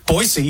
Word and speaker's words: Boise: Boise, you have to say it right. Right? – Boise, Idaho, right? – People always say it with a Z, right? – That's right.